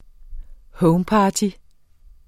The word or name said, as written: homeparty